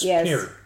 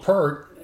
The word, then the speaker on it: Peart: I've been calling him Neil Peart my entire life. – And you've been wrong. – Okay. – Because I've heard him say it's like ear. – Okay. – It's spelled the same way. It's Peart. – And they say it that way on the video. – It's just that people got lazy and called him Peart.